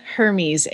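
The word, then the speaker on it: Hermès: Google it, and it's called, spell it like this, Hermes, H-E-R-M-E-S. – Oh, yeah. Hermes is the one that had the tack and stuff that was like millions of dollars. – It's called Herme, Glenn.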